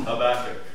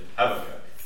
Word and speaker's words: Habakkuk: It's in what we're going to look at, the prayer principles in Habakkuk. That's how I pronounce it. Unless I forget and I say Habakkuk.